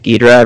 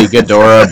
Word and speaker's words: Ghidorah: I think it's pronounced Ghidorah, but I've always pronounced it Ghidorah ever since I was, like, 6, so I can't help myself.